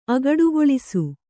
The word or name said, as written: ಅಗಡುಗೊಳಿಸು